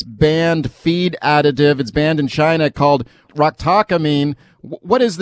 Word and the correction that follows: ractopamine: It's pronounced ractopamine.